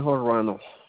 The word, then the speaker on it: Ruano: Editor Armando Cornejo Ruano.